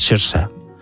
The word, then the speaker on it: Saoirse: que como es gaélico es Saoirse. Es como se pronuncia.